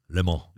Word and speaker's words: Le Mans: Le Mans.